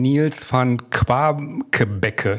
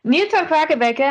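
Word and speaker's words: Niels van Quaquebeke: Warum gerade jetzt diese Publikation, die Sie gemeinsam mit dem Psychologen Nils van Quabkebecke, ich hoffe, ich habe es richtig ausgesprochen, veröffentlicht haben? – Auf jeden Fall, das war flüssig ausgesprochen. Ja, zusammen mit meinem Kollegen Nils van Quakebecke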